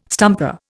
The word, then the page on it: stumper: Indian